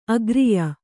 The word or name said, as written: ಅಗ್ರಿಯ